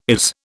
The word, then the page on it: is: British English